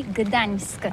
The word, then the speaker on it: Gdańsk: C'est pourquoi on dit Gdańsk.